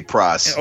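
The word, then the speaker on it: Pras: Praz, is that Michelle? how are you pronouncing that? – I'm just say Praz.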